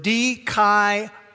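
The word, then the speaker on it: dikaioō: And the main verb is the verb dikaiō in the original language. It's D-I-K-A-I-O-O with a long mark over the second O. D-K-I-A-O.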